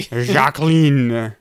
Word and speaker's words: Jacqueline: Jacqueline. It's French. It's French way.